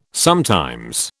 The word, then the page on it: sometimes: British English